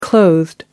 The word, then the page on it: clothed: American English